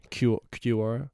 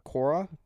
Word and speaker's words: Quora: Quora. – Quora. – Q-R. – Q-U-O-R-A.